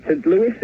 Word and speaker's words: St. Louis: St. Louis, is that how I pronounce it? – Oh, that's close enough.